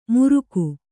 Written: ಮುರುಕು

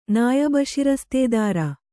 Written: ನಾಯಬಶಿರಸ್ತೇದಾರ

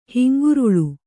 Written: ಹೆಂಗರುಳು

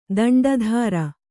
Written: ದಂಡಧಾರ

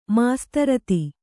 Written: ಮಾಸ್ತರತಿ

